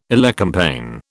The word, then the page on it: elecampane: British English